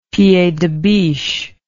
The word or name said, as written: pied de biche